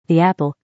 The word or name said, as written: the apple